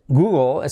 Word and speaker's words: Google: Por cierto, Google es como Hugo, pero cambiando la G por la H. Hola, Hugo. Hola, Google. Google. Porque la L al final de palabra, ya lo sabes, que casi no se pronuncia, pero hace ese efecto de O-O.